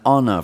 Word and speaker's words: honor: It would be an honor for me. Ecco, non si pronuncia l'H.